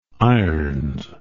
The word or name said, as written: irons